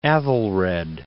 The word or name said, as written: Aethelred